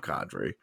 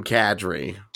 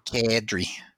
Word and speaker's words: Kadri: It's the way he told us to pronounce it. I've got to break the year's habit of calling him Kadri. – Yeah. – He says it's Nazem Kadri. – Kadri.